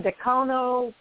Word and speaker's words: Dacono: in Dacono, Colorado. Did I say that right, Phil? – Yeah, that's that's that's close enough.